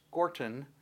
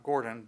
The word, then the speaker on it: Gortyn: It's actually pronounced Gorton, which is not Gordon, but mm-hmm. Gorton. It's spelled G-O-R-T-Y-N.